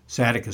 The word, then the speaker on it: Zadok: Zadok is how we can pronounce it,